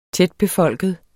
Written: tætbefolket

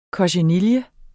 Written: kochenille

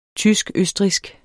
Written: tysk-østrigsk

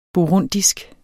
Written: burundisk

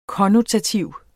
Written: konnotativ

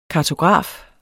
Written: kartograf

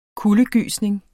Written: KULDEGYSNINGER

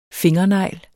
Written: fingernegl

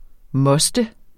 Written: mostes

